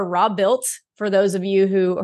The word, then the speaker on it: Robuilt: Listen, I'm just honored that you said it right. Most people say Robilt, and I'm like, you don't watch the channel, but you know, I appreciate that.